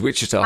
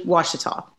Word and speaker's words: Ouachita: And for a start, that's helped me pronounce it because I thought it was Wichita. – I know. It's an Indian name. And so the O-U-A-C-H-I is spelled like Wash. So everyone always calls it Ouachita, but it's actually Ouachita.